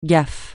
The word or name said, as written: gaffe